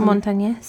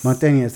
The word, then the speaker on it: Montañez: Montañez. Montañez, thank you, story authentically.